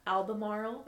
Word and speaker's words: Albemarle: Albemarle.